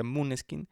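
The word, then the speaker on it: Måneskin: Hay un grupo actualmente llamado Mooneskin, que se escribe Maneskin, pero por como las veces que lo he visto en vivo se pronuncia Mooneskin, que traen una onda muy andrógina.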